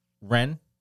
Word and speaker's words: Wren: So apparently they were all brought together by this woman, Jenny Wren. – Okay. – I want to say that. I hope I'm saying that right because it's a W-R-E-N. – Yeah, Wren is, yeah, that's a proper.